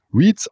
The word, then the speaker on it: REITs: Ich habe tatsächlich auch den einen oder anderen Leser, der sich nach Interviews mokiert hat, ja, wäre inhaltlich äh, alles schön, aber warum nicht denn immer die ganze Zeit äh, Reiz sagen würde, das wäre doch ein englischer Begriff und äh, müsste Reads ausgesprochen werden, aber ich sag mal auch, ne, wir sagen ja auch Hyundai und nicht koreanisch Hyundai.